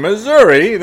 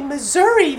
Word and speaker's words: Missouri: Oh, excuse me, Mr. Grimes, but I think I heard you say Missouri that time. – Yes, I did. Folks in the city of St. Louis do prefer that pronunciation. Missouri. But anywhere else in the state, you'd better say Missouri.